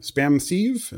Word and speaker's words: SpamSieve: Yes, I ran uh, spam SpamSieve.